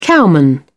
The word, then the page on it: cowman: British English